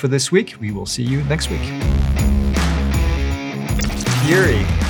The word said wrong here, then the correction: fiery: Fury. How do you say that word? Fiery. Oh, yeah. Fiery.